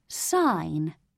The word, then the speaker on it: sign: G in sign must silent be. In signature, pronounce the G.